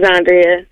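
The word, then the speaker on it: Xondria: This is Zandria.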